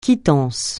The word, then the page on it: quittance: francais